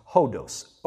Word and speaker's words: hodos: The word for way in the New Testament is actually a word that is pronounced hodos, H-O-D-O-S.